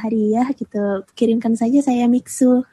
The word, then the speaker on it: Mixue: Eh, yang bacanya yang bener Mixu atau Mixu sih? – Eh, Mixu. – Mixu. Mixu yang benar Mixu.